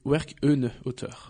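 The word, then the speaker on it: Werk ohne Autor: Je pense qu'on dit Werk on Autor.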